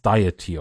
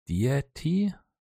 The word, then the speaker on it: Deity: ich hatte es Deity of War ausgesprochen, glaube ich, aber in Wirklichkeit heißt es Deity,